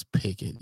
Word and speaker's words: Pickett: You can forget about him because you didn't even say his name right. – What is it? Kenny Pickett? – It's Pickett, yes. – Oh, like a picket fence?